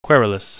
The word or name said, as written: querulous